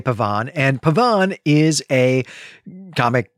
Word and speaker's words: Pavane: I'm going to pronounce it Pavane, which is, I think, what you would in French.